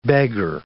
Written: beggar

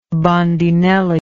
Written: bandinelli

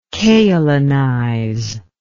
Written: kaolinize

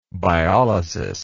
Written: biolysis

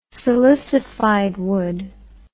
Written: silicified wood

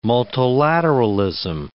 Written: multilateralism

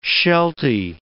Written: sheltie

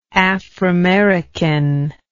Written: aframerican

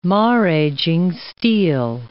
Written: maraging steel

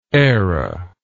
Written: eyra

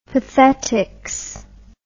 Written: pathetics

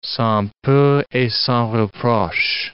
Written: sans peur et sans reproche

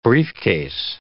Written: briefcase

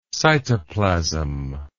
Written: cytoplasmically

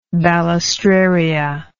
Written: balistraria

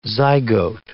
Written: zygote

